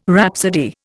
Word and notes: rhapsody: Indian

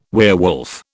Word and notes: werewolf: British English

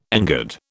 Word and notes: engird: British English

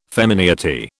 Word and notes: femineity: British English

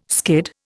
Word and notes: skid: Indian

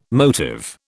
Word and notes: motive: British English

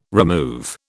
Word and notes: remove: British English

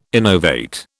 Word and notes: innovate: British English

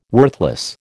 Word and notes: worthless: American English

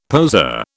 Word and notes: poseur: British English

